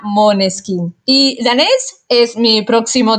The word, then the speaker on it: Måneskin: Y bueno, ellos son muy buenos, pero ese nombre se pronuncia Måneskin porque sale de danés, el idioma de Dinamarca, como dicen ustedes, ¿verdad?